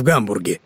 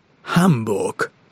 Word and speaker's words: Гамбург: Кстати, знаете, никак не пойму, почему это у нас так твердо произносится «Гамбург». Неправильно это. Тамошние жители называют свой город «Хамбург».